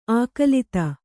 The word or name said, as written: ಆಕುಲಿತೆ